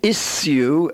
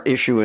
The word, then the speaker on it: issue: And in this grouping, the sh is spelt S-U and S-S-U. The words are sure, ensure, assure, fissure, issue, and tissue. What would be the British pronunciation of the last two words? – Well, in England, they would say issue and tissue, a little different from our issue and tissue.